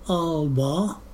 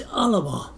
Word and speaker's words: Alba: And it wasn't until, maybe a year or so ago, that I reckoned the proper pronunciation, that is the Gaelic pronunciation, isn't Alba, it's Alaba.